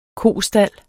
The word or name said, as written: kostald